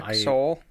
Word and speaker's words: Seoul: S-E-O-U-L? – It's pronounced Seoul? – The capital of South Korea, Seoul? – I wasn't sure exactly how to pronounce it.